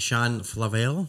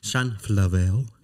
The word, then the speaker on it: Shan Flavelle: So so I would like to welcome, I hope I pronounce it right, Shan Flavelle. – That's right. – Yeah, see? – Yeah. – See, well done. Shan Flavelle.